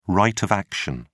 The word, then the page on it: right of action: British English